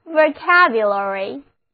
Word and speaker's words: vocabulary: O 字母发作 a，第一个 A 字母发作 a 的梅花音，第二个 A 字母同样发作 a，重音放在第二个音节上，单词就读作 vocabulary，词汇表，词汇。我们的节目就是帮助大家扩大词汇量。